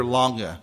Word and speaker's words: Lange: I like, there's a German Calvinist commentator named Johann Peter Lange. In English, we would probably pronounce his last name as Lang.